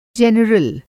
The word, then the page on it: जेनरल: Hindi